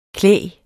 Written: klæg

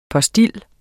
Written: POSTIL

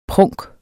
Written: prunk

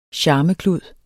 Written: charmeklud

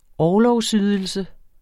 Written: orlovsydelse

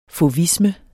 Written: fauvisme

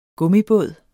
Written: gummibåd

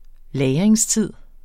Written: lagringstid

